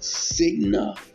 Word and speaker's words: Signet: Now, this particular business is called Signa Jewelers, which is spelled S-I-G-N-E-T, Signa Jewelers, J-E-W-E-L-E-R-S, Signa Jewelers. The stock symbol is S-I-G.